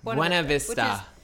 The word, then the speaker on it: Buena Vista: Yeah, it was produced by Beacon Pictures and distributed by Universal Pictures in the United States and Canada, and internationally by, I can never pronounce it, but Bienio Vista International, which is Disney. – Buena Vista.